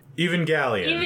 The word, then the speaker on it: Evangelion: But this episode is all about Neon Genesis Evangelion.